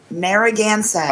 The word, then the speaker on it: Narragansett: Narragansett.